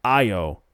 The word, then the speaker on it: Ayo: Her name is Ayo, not Ayo.